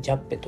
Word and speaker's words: 川崎船: えジャッペ」という川崎線と書いて「ジャッペ」というね、え物語なんですが、え主人公はね若者です。